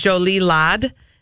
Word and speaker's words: jolie laide: the French word is jolie laide,